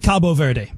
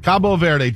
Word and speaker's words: Cabo Verde: I like Cabo Verde. – Cabo Verde.